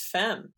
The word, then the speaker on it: femme: It's interesting that you pronounce femme as femmy. – Oh, is it femme? – I've never heard that before. It's femme.